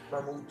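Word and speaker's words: Mahmood: Brividi von, ähm, ich weiß nicht, spricht man ihn Mahmoud oder Mammut? Mammut.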